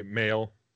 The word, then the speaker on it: Mayall: Is it pronounced Mayall? – You can say male. Male.